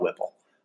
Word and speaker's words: WPIAL: So one of them, Lamont Wade, 2 years in, five-star recruit out of the Pittsburgh area, the, I don't know how you say it, Whipple, W-P-I-A-L, it's the big, like, league out there. I call it Whipple.